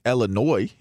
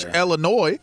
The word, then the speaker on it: Illinois: I'm just saying, you know, if it was Josh Illinois, everything would have worked out perfectly fine. – Silent S. – You know, the silent S.